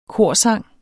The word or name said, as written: korsang